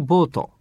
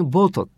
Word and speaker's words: vote: それはキャスティングボートを握っているなんていう時に使う、このキャスティングボートって言い方なんですが、まずは重要なのはキャスティングボートっていう時にですね、ボートって発音しちゃうと、なんか本当にそれってボートよみたいな、なんだかわかんないけど、つまりこの場合の vote V-O-T-E、これは票ですからね、 V-O-T-E vote cast your vote のような言い方。